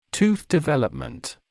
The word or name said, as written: tooth development